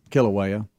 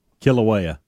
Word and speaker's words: Kilauea: Now, how you say it again? – Kilauea. – Kilauea.